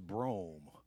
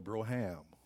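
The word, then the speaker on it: Brougham: And I didn't know when I was coming up that the right pronunciation for this type of Cadillac was Brome. We call it a Broham. You remember? Got me a Broham.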